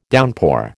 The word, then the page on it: downpour: American English